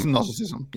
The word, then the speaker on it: Gnosticism: Gnosticism. – Yeah, it's Gnosticism.